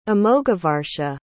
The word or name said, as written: amoghavarsha